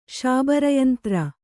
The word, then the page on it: ಶಾಬರಯಂತ್ರ: kannada